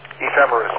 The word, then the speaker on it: ephemeris: Emphasis. How do you say that word? I can't have trouble saying that. Emphasis? Emphasis? – Ephemeris, okay. So, Interactive Computer Ephemeris, I-C-E.